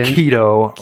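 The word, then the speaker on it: Chaeto: So I got some Chaito. – It's pronounced keto. – Keto.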